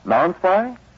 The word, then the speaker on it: Non-Spi: How do laboratories pronounce N-O-N-S-P-I? – Non-spy?